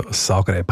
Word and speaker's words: Zagreb: Ich weiss, welche Richtung dass du willst, aber die spricht man eher mit S aus. Also Zagreb, Hauptstadt von Kroatien und eine riesen Fußballstadt.